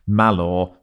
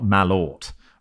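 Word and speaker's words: Malört: Just to let you know that Malor is pronounced Malor, not Malort.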